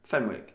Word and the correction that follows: Fenwick: Okay, that's not right. – What is it? – Fenix. – Yeah. – Why am I calling it Fenwick? – Because it's spelt Fenwick.